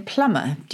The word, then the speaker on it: plumber: And that explains why we have the silent B in plumber.